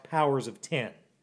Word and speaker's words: Powers of X: One of them sit right now, and the other one of them's called House of X, and the other one's called Powers of X, which is actually pronounced Powers of Ten.